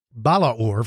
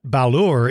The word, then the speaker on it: Balaur: So I'm going to call it Balaur from now on. But Balur is usually the way you'll hear most people pronounce it.